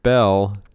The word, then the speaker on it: Beall: Oh, B-E-A-L-L. – It's pronounced Bell.